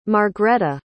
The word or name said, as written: margretta